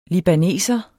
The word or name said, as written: libaneser